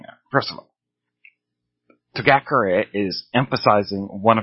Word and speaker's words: Togakure: So toga, the ga, when they emphasize togakure, the ga is improperly emphasized. Each syllable must be equally stressed in Japanese words. Therefore, togakure is the proper way to say it.